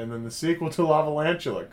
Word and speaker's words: Lavalantula: Lavalantula. – Lavalantula, and then two lava, two lantula.